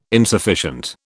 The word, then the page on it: insufficient: British English